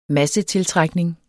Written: massetiltrækning

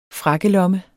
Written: frakkelomme

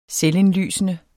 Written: selvindlysende

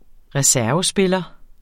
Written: reservespiller

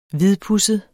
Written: hvidpudset

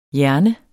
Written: JERNER

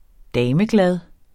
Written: dameglad

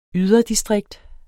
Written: yderdistrikt

